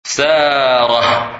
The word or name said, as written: Sarah